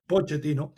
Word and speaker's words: Pochettino: Perdonen que le pronuncié el nombre en italiano en vez de en español. Pochettino.